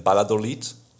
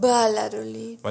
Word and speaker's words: Valladolid: Wir sind in Valladolid, diese Stadt schreibt man mit V, aber sie wird mit B ausgesprochen. Why the heck?